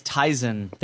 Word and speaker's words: Tizen: It's Tizen.